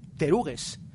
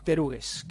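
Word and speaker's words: pteruges: Terugues, con P al principio. Como psicología, que no se pronuncia la P, pues igual, PT. Teruges.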